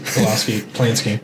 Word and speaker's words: Polanski: Polanski. I said it right the first time.